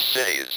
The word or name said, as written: cities